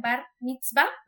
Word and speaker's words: bar mitzvah: Eh, supongo yo, es el mar, bar, ¿cómo se pronuncia? Bar mitzvah. – El bar mitzvah. Sí, se pronuncia, ajá, exactamente.